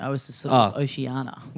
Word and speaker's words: Oceania: Even on like the Australia or like the Oceania yep. – service. I've never known how to pronounce that word. – Oceania.